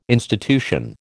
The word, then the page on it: institution: American English